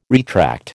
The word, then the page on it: retract: American English